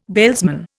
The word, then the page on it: bailsman: Indian